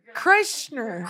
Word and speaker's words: Kreischer: Chrysler? – Chrysler? – I don't know. – Bert Kreischer. – No, it's Kreischer. Not. It's Kreischer.